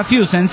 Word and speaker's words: Füssen: El musical es en la ciudad de Füssen, a ver si lo pronuncio bien, se pronuncia Füssen, sí, dice Füssen, y está una masa, tanto la ciudad como el musical.